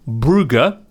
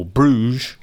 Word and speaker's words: Brugge: Yeah, there was Man United Brugge, or Bruges, to use the traditional pronunciation, which featured a hilarious own goal to kick off proceedings.